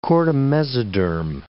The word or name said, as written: chordamesoderm